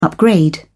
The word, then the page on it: upgrade: British English